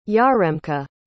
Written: yaremka

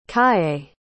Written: calle

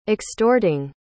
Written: extorting

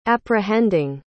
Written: apprehending

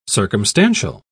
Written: circumstantial

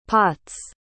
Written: pots

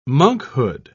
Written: monkhood

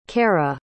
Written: cara

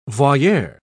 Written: voyeur

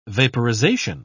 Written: vaporization

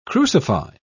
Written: crucify

